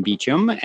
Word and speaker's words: Beachum: You can search Brandon Beachum, and my name is spelled like the beach and U-M.